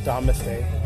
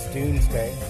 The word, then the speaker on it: Domesday: Actually, it's pronounced Doomsday Book, not Domesday, although I don't know, it looks like Domesday, but it is Doomsday.